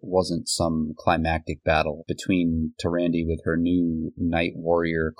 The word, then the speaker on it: Tyrande: But we also have Tyrande, I think is the pronunciation. And I call it Tyrande.